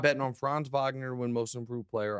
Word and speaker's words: Wagner: Franz Wagner is how you pronounce it. – Wagner, mm-hmm. With a V, yes.